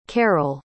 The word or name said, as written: karel